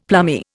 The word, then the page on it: plummy: Indian